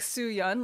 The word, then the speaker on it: Soo-yeon: It's annoying because like Soo-yeon, like how my name is spelled, it's like Y-E-O-N. And it's like, it shouldn't be spelled that way. It's like Eon.